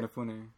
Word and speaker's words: Inafune: Inafune.